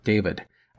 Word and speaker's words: David: I want to mention that I have, a few times, mispronounced the name of the expedition's scientific director, Edgeworth David. I have called him Davis instead of David.